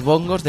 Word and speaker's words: bongos: Capcom está desarrollando una recreativa basada en el Jungle Beat, el juego de plataformas controlado por los bongos de GameCube. Oye, por cierto, ¿qué manía tienen todos los portales llamarles bongos? – Bongos. – Sí, siempre pone el acento en la última en la O.